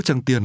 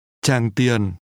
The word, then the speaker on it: Tràng Tiền: Chị cho hỏi, phố Tràng Tiền ở đâu? Tràng Tiền is pronounced with two low falling tones.